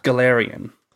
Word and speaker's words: Galarian: Galarian.